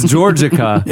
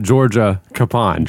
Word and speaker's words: Georgica: It's Georgica. – It could Pond. Be the name George, and then like a. – It's Georgica. But Jesse pronounced it Georgia Capond.